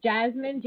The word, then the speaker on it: Jasmin: That's Deluxe Home Care, D-E-L-U-X-E. – D-E-L-U-X-E, okay. – You got it. My name is Jasmine, J-A-S-M-I-N.